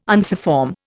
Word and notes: unciform: Indian

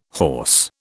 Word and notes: hawse: British English

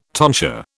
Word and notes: tonsure: British English

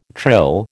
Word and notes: trill: American English

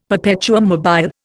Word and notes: perpetuum mobile: Indian

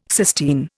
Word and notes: cysteine: Indian

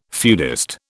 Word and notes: feudist: British English

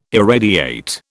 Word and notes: irradiate: British English

